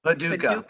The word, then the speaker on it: Paducah: Paducah.